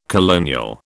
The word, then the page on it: colonial: British English